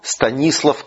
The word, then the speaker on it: Станислав: Он Станислав Ковальский, с ударением имени на «и».